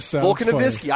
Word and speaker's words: Volkanovski: Okay, how do you Volkanovski? Volkanovski.